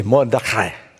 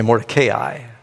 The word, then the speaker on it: Mordecai: If you're Jewish, you say Mordecai. You don't have to say it like that. And some people say Mordecai. I've said it like that. I've even heard, just yesterday, I heard a Baptist pastor saying it that way.